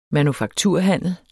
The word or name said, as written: manufakturhandel